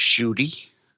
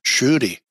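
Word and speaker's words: Ncuti: His first name is pronounced Shooty. – Shooty.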